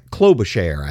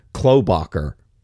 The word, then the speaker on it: Klobuchar: I never can say her name right. Klobuchar, I think. I always want to say like Klobacher.